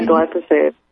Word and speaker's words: glyphosate: And I finally just got disgusted with the fact that you can, how do you pronounce that word, glyphosate? – Yeah, glyphosate. – Mm-hmm. Is that how you pronounce it? – Yeah.